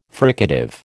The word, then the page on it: fricative: American English